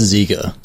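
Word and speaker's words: Dziga: Ziga Vertov? – I've always heard it pronounced Ziga.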